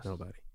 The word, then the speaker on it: NBDY: Yes, I'm pretty sure that's how you pronounce it, right? Yeah, N B D Y. Yes, nobody. Nobody.